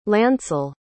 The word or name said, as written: lancel